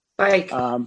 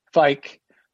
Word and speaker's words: Feick: I move to have Andrew, I'm going to mispronounce his last name, so I'll spell it F-E-I-C-K. – Fike. – Andrew Fike. – Fike, to be the alternate member to the Zoning Hearing Board for a term ending December 31st, 2023.